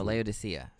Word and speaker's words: Laodicea: Laodicea? Is this the... – Yeah, Laodicea. – Laodicea? – Or no, Laodicea. Sorry. – Laodicea. Laodicea. All right, thank you.